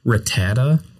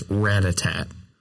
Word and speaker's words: Rattata: You have no idea how long I thought Rattata was Ratatat.